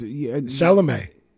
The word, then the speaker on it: Chalamet: Charlamagne. Ain't that how you say his name? – Oh, the little actor dude? – No. It's Chalamet.